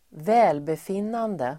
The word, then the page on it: välbefinnande: [v'ä:lbefin:ande]